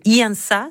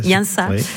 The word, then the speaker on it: Iansa: Je pense que c'est un peu dans cet élan qui Iansa, c'est comme ça que ça se prononce. – Iansa.